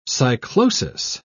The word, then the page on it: cyclosis: British English